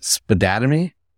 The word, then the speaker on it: Spadattimy: This is, how do we say it? Spadattimy? – That is correct.